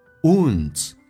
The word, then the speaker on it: wounds: Do not let your wounds, 这个单词长元 U 加个前鼻音 N, wounds, alright, 伤口伤痛 wounds, transform, 重音在后面 F 上 transform, 重度卷舌。